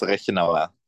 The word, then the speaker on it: Rechenauer: Um, it's really hard to pronounce, honestly, if you don't speak German. It's Rechenauer.